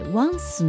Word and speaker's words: once: え once の u ははではありません。う w の発音記号ですので口をぐっとすぼめて once。